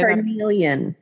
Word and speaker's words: carnelian: Although I don't have the carmeline. Wait, how do you say carnelian? – Carnelian. Excuse me.